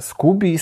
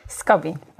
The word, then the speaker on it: Skobi: E, właśnie, czy ja w ogóle dobrze wy, wy, wymawiam nazwę Skubi, Skobi, bo też pisałem o mhm. tym artykuł i na początku sam nie wiedziałem. – Skobi. E, I Skobi, ta mhm.